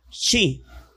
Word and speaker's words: chi: Y en el griego, la X se pronuncia chi.